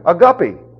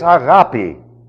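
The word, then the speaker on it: agape (ἀγάπη): Or if you prefer the fish version, a guppy. That's incorrect, by the way. It's agape. That's the way we pronounce it today.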